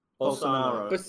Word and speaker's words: Bolsonaro: And Bosnio, how do you say his name? – Bolsonaro. Bosnia being, is a country.